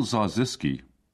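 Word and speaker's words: Zarzyski: Paul Zarziski, if you want to remember my second name, it rhymes with bar whiskey.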